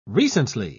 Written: recently

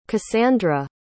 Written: casandra

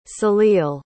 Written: salil

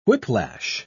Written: whiplash

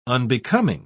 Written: unbecoming

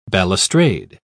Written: balustrade

